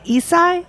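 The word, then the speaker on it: Issei: which is when the Nisei and the Isai, am I saying that right? – That's correct.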